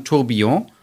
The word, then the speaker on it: Tourbillon: Ja, es spricht man Französisch aus. Also dann Tourbillon.